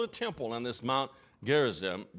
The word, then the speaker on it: Gerizim: And she said this mountain was Mount Gerizim,